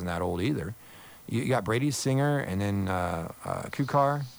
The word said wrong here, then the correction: Kowar: Is that how you pronounce it? – Kowar. Yeah, Kowar. – Jackson Kowar. – Yeah, Kowar. Yeah.